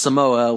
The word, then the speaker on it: Samoa: like actually pronounced Samoa, like, you know, someone who is from the islands would, and...